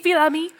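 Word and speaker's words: Fils-Aimé: Reggie Filami.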